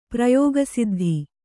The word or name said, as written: ಪ್ರಯೋಗಸಿದ್ಧಿ